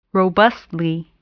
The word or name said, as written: robustly